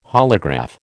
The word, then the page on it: holograph: American English